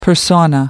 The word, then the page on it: persona: American English